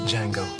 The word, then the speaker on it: Django: Quentin Tarantino sparked controversy with his slave revenge western, Django. The D is silent.